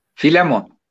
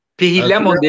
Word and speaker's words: Filemon: Filemon. – Filemon diye yazılır. Filemon evet. diye okunur. – Evet. evet. – Filemon çok kolay aslında. Fileyi düşün abi. Mon ekle tamam.